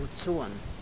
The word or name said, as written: bucon